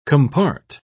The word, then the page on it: compart: British English